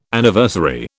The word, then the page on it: Anniversary: British English